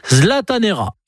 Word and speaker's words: zlatanera: Et si vous allez en week-end à Stockholm, n'hésitez pas à prononcer le verbe zlataner à l'infinitif. Ça se prononce zlatanera.